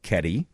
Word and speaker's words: Kedi: Kitty? – That's a word. – Well, no, it's spelled K E D I. – Oh, Keddie, all right.